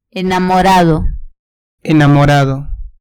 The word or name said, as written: enamorado